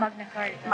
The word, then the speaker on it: Magna Carta: Magna Carta? – Magna Carta.